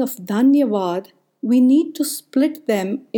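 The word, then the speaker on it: Dhanyavad (धन्यवाद): Digging into few Sanskrit texts, which is one of the ancient Vedic languages, I found out that the word thankful is commonly said as Dhanyavad. It's spelled as Dhanyawad, and the word grateful is commonly said as Abhari, Abhari.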